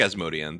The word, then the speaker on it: Asmodean: Some people were, as soon as he was cast, they're like, could this possibly be Asmodian? Or Asmodean. That's another one. I'm like, which way should I pronounce it? – I think Asmodean. That's how they say it in the audiobooks, at least.